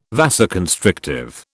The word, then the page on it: vasoconstrictive: British English